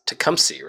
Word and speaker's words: Tecumseh: Uh, to it's not it's not how it's spelled. It's Tecumseh, right?